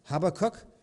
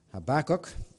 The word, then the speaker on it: Habakkuk: And I hesitate to say that because in Northern Ireland we pronounce that Habakkuk. In Greenville, South Carolina, they pronounce it Habakkuk.